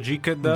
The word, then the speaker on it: Geeked: Io l'ho sempre pronunciato Geeked. – Geeked? Okay, perché uno è Geek e quindi è Geeked Week.